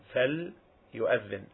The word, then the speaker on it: فَلْيُؤَذِّنْ: So, فَلْيُؤَذِّنْ, listen to my pronunciation, فَلْيُؤَذِّنْ, the lam, فَلْ, there is a lam, letter.